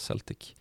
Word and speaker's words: Celtic: Ja, Celtic då som ju, ja det stavas ju Celtic men det uttalas Celtic.